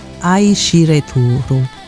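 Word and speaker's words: Aishireturu (愛してる): No, ti amo in giapponese si pronuncia Aishireturu.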